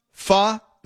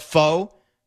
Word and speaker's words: pho: It's called it's pronounced pho, but nobody calls it that. People just call it pho.